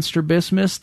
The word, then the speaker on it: strabismus: He had strabismus. Am I saying that right? – Yep. Mm-hmm.